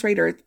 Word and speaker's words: Roeder: I think it's pronounced Raider, but it was spelled differently. It was spelled R-O-E-D-E-R. And his wife, Anna Katharina Tauber, both born around 1670 in Bern, Switzerland.